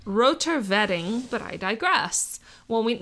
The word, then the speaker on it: Wedding: Vetting, oh, thank you, Lee. He literally put in pronounced vetting. Thank you, because it's spelled wedding.